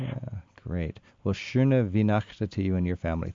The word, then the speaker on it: Schöne Weihnachten: Schöne Weihnachten. – Schöne Weihnachten. – Schöne Weihnachten.